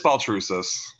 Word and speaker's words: Baltrusis: It's Baltrusis.